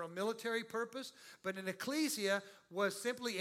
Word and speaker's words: ecclesia: It's pronounced ecclesia because I checked with our local scholar, Nick Birmingham. I went to the Nictionary, the Nicothoris, and found out that this is the correct way to say it.